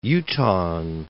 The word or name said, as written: Utahn